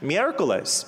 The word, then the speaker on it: miércoles: Miércoles, because in Roman mythology, Mercury was worshipped.